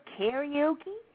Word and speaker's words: karaoke: Karaoke? Like that? – Karaoke. – How do you say it? – Karaoke. Karaoke. – Karaoke. – Yeah, that's how we say it in Japan.